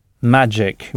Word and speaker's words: magic: Magic, oui, avec le G prononcé J, magic.